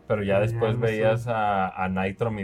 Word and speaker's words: Nitrome: Y la novedad fue Shovel Knight Dig, que es un juego hecho con Nitrom, o Nitrome, ¿cómo lo pronuncias? – Nitrom. – Sí, qué bonito, Nitrom, uff.